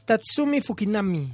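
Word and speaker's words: Tatsumi Fujinami: ¿Cómo se pronuncia? Tatsuna. Tatsumi Fukinami. Así es, perdón, es que mi japonés es muy malo.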